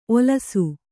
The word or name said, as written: ಓಲಿಸು